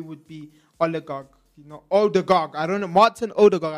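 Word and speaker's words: Ødegaard: I hope I say his name right. Oligarch. – Oligarch. – No, I'm saying his name so wrong. I'm butchering his name. I am so sorry. Odegaard.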